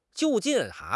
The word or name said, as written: 就近